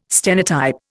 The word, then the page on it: stenotype: Indian